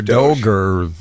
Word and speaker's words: DOGE: So this story broke, and this is the DOGE department, D-O-G-E. I'm assuming that's how you pronounce it, right? – Do we say DOGE or DOGE? – It's DOGE.